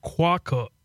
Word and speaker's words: quokka: Okay, so it's quokka. Quokka. – Okay. – Quokka. That's how you say it.